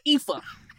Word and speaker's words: Aoife: So I've been butchering Alpha's name. It's Aoife.